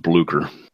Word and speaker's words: Blücher: I am, but I'm not a German speaker, but I've always heard it pronounced Blücher.